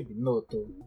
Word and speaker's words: Binotto: Binotto.